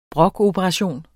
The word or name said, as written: brokoperation